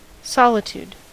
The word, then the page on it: solitude: [ˈsɔ.lɪ.tuːd]; American English